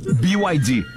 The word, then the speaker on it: BYD: BYD. – Que não pude deixar passar essa oportunidade. Fetter faz questão de explicar que o correto é pronunciar... – BYD. – Falando o nome das letras em inglês.